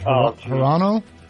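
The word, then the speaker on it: Hirono: How do you say the lady's name, the senator from Hawaii? Is it Hirano? – Sure.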